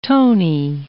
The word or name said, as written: Tony